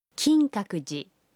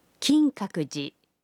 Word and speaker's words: 金閣寺: La prononciation est 金閣寺, 金閣寺.